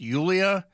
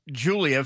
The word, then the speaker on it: Yulia: He's heard it before and he's told one last time it's Yulia. Not Julia, if you want to mispronounce it, it's Julia, but it's with a Y to make it easy for you.